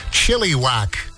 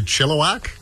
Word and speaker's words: Chilliwack: I had a coworker once who pronounced the name of that band Chilliwack. And I said, hey, dude, by the way, it's actually Chilliwack.